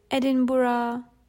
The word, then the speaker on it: Edinburgh: We visited Edinburgh. That's how you pronounce the name of the capital of Scotland.